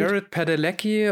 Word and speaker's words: Padalecki: Heißt der Jared Padalecki, ähm, der Sam spielt? Jared Padalecki und Jensen Ackles.